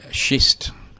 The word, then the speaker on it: schist: And there's another thing called schist, and I did pronounce that correctly.